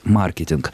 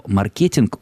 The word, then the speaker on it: маркетинг: И та, и другая форма до сих пор воспринимаются как равноправные, хотя последнее время говорят о том, что предпочтительнее сохранять ударение как в английском языке, то есть «маркетинг». Хотя на одной из последних конференций, посвященной как раз тотальному диктанту, один из специалистов в области культуры речи говорил о том, что совершенно непонятно, почему мы должны следовать традиции английского ударения, и слово «маркетинг» более удачно, так как ударение падает на середину слова, а в русском языке есть такая своеобразная тенденция к ритмическому равновесию.